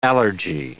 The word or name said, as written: allergy